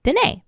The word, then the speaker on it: Denee: I'm at Danae says, Danae spelled D-E-N-E-E. – And pronounced Danae.